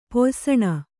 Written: ಪೊಯ್ಸಣ

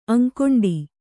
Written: ಅಂಕೊಂಡಿ

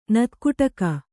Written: ನೆತ್ತಗೊಡಗೆ

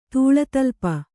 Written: ತೂಳತಲ್ಪ

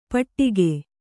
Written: ಪಟ್ಟಿಕೆ

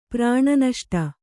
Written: ಪ್ರಾಣನಷ್ಟ

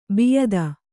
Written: ಬಿಯದಿ

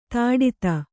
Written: ಧಡೂತಿ

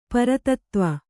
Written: ಪುರಾತತ್ವ

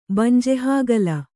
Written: ಬಂಜೆಹಾಗಲ

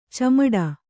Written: ಚಿಮುಟು

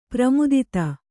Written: ಪ್ರಮೋದೂತ

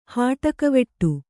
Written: ಹುಟ್ಟುಕಿವುಡ

